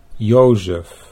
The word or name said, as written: Jozef